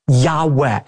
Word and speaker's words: Yahweh: What we actually have there in the original manuscripts are the letters Yud Heh Vav Heh, which are God's personal name that most Semitic scholars believe is pronounced a breathy Yahweh.